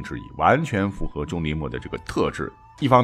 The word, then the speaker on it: 钟离昧: 不是钟离昧吗？实际上，这个古代发音应该是墨啊，很多人把它读成昧，或者把这个字呢就错读为味道的味啊。实际上叫钟离墨是比较准确的。